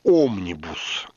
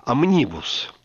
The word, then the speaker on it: омнибус: По-русски вроде бы принято говорить «омнибус», но в английском языке, откуда пришло это слово, оно произносится с ударением на втором слоге «омнибус».